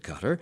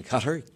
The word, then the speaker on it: Qatar: First of all, like so many words that begin with Q in the Arabic language, there is no U after the Q in Qatar. Q-A-T-A-R. What is the correct pronunciation? – Well, people are very safe on that because Qatar is a perfectly acceptable way to say the name if you are speaking English, and Qatar is a close approximation to the Arabic.